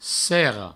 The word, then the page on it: serra: serro